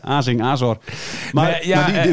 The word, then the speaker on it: Azor: Azor, hij deed de klemtoon anders. Ik zeg altijd Azor, maar het is Azor.